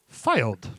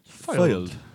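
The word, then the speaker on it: Fylde: Went on, did the Google pronunciate, and they said, filed. – All right. – So in the little British accent. Went, filed.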